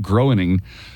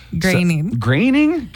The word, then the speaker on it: Groening: Creator Matt Groening. – Graining? – God.